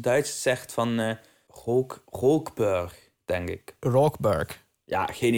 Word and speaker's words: Rookburg: Kan Rookburg uitspreken, maar ik krijg, die R er tussen niet uitgesproken. Rookburg. ja. – Ja, maar dat komt omdat je in Limburg woont. – Rookburg.